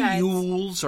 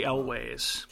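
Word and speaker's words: Elwes: Is it Carrie Yules or Carrie Elways? – Carrie Yules. – I've heard it pronounced both ways. – I think it's Yules. – Yules. Like Y-U-L-E-S.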